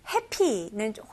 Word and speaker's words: happy: 근데 발음은 사실 달라요. 네, happy는 훨씬 더 짧은 모음이고요. 여기서는 A 발음을 조금 더 길게 해주셔야 돼요. 제 입모양을 보시면서 같이 한번 따라해볼게요. happy. 네, happy라고 하면 훨씬 더 이렇게 짧지만 happy, happy. 입을 더 벌어주셔야 돼요.